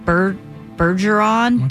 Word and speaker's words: Bergeron: Tom Bergeron? – You said it right.